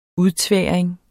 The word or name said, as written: udtværing